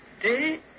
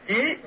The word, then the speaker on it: trí: Phật ngôn hữu nhân văn ngô thủ đạo, hành đại nhân từ cố trí. Cái chữ này cũng đọc là chí, với chỗ đọc là trí.